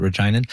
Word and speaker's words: Reginan: Is that how you say it, Reginan? – Yes.